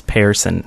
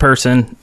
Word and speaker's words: Pearson: And in the news today, Marcus Pearson, and that is how you say his name, by the way. It's Pearson, not person, not parson, not whatever.